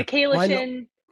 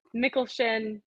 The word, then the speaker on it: Michalyshen: Michalyshen, Michalyshen, Michalyshen. Like sometimes someone's like, how do you say it? And it kind of takes me a second to, because I've heard it so many different ways now. And I kind of just accept all of them as correct, but they're not.